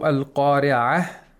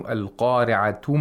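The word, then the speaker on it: القارعة: So al-qari'ah, mal-qari'ah. But if you continue, al-qari'atu mal-qari'atu wa ma adraka mal-qari'ah. – Mm-hmm. – So if you stop, you have to pronounce it like a ha.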